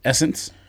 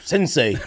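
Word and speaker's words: SSENSE: And by the way, I didn't know the store was, that's how you pronounce it, Essence. You know what I'm talking about? That Canadian store that's S-Sense. – I thought it was like Sensei. – I had no idea it was Essence.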